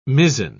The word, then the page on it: mizzen: British English